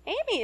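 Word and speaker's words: Amie: Amy is wildly imaginative and different, you know?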